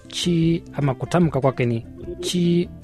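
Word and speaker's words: xi: Ama pia X na I. Kwa Kichina tunasema ni chi. – Chi, X-I ni chi.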